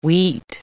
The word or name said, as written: wheat